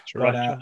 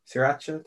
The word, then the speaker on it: sriracha: Sriracha. But, sriracha, thank God.